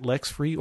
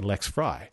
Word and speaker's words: LexFri: And then I've got my Twitter name, LEXFRI, and I got people who want to pronounce it Lex Free, or even sometimes John Syracusa, whom Dave W. knows, pronounces it Lexi Free, which I don't understand at all. For me, I look at my name and I say Lex Fry, but that's so.